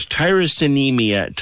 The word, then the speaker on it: tyrosinemia: It's called tyrosinemia type 1, if I'm saying that correctly. – You are indeed.